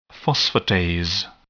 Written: phosphatase

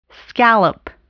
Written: scallop

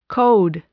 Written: code

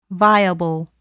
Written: viable

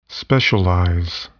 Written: specialize

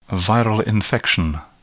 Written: viral infection

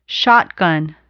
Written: shotgun

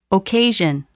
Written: occasion

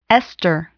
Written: ester

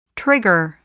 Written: trigger

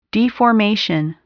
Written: deformation

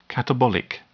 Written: catabolic

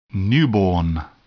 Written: newborn

